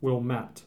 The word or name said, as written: Wilmette